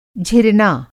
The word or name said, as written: झिरना